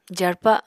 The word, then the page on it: jarpa: apatani